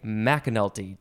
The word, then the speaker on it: McAnulty: We have today Sarah, and I think I'm going to pronounce it correctly, McAnulty.